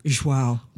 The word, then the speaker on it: João: João. – João.